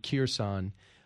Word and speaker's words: Kherson: I think the K is silent, but I always called it Kherson.